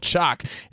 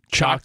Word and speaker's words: Choq: So thankfully, there's a new champion of natural testosterone boosters called Chalk. It's spelled C-H-O-Q. – Chalk.